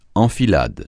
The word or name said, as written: enfilade